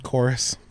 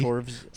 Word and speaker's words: Chorus: Chorus, but it's spelled with a V. – Chorvs.